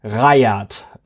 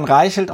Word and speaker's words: Reichelt: Reiert. Ähm, wir müssen seinen Nachnamen jetzt immer falsch aussprechen, damit, damit wir nochmal unterstreichen, dass wir nicht wissen, wer Jürgen eigentlich Reich.